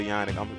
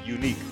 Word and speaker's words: Yannick: I ain't going to say Yannick. I'm sorry. His name is Unique. Y'all get his name right now.